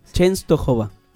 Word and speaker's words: Częstochowa: Częstochowa. – ¿Cómo? – Częstochowa. – Częstochowa. – Częstochowa. – Bueno, ahí está. Ya me corrigió el padre ese. – Uy, mirá, ahí se está enojando. Częstochowa.